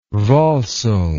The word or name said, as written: volsung